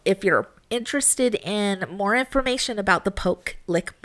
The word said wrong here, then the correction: Pope Lick: Monster. – Pope. – Right. Oh, yeah, I said it wrong this time. Jeez. The Pope Lick Monster,